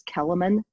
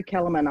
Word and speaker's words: Kelemen: It's Kellerman, @Kellerman, KELEMEN.